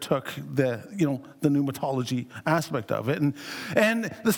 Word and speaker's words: Pneumatology: Pneumatology. Well, actually, the P is silent. Pneumatology, anyone? Holy Spirit.